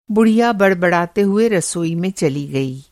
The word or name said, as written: बड़बड़ाना